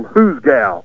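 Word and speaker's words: hoosegow: And then the typical cowboy word for a jail became Who's Gal, spelled totally different, pronounced pretty close to the same.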